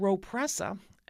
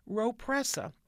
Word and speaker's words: Rhopressa: Another drug called Ropressa, and I'll spell that R-H-O-P-R-E-S-S-A, Ropressa, recently approved, belongs to a new class of medicines called Rho kinase inhibitors. That's R-H-O is one word, and then kinase, K-I-N-A-S-E.